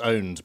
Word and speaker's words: pwned: And I love the fact that they say, well, it's pronounced owned, but written pwned.